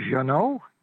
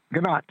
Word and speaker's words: Ganot: By the way, you have to tell the Hawaii coach, they used to pronounce his name Janot. Now, Janot sounds better than Ganot. – Tim's got the proper pronunciation. Tim, what is it? – Yeah. Aran Ganot. – Ganot.